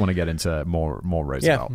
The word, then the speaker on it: Roosevelt: It is Roosevelt. That's how the man himself would choose to pronounce it, despite the various controversies, despite the 4 pronunciation options that my pronunciation dictionary that I normally go to gives me.